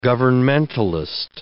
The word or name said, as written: governmentalist